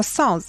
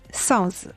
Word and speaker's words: SUNZ: 因为 Rise 的粉丝名名称是粉丝自己选的嘛，自己投票选择出来的。一开始的粉丝名叫 Sounds，哎，怎么读怎么读才比较顺？S U N Z Sounds，我我听上去不是很妙。